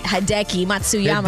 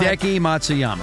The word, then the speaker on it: Hideki Matsuyama: No, I didn't say that right. Hideki Matsuyama? That's how it Hideki. – Is. Right? Hideki Matsuyama. – Hideki Matsuyama.